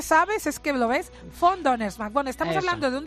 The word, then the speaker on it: von: No, perdona, esto es von. Y si me dices von, es F.